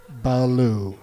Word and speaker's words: Ballou: Not just adding a syllable to the word blue. It's Ballou.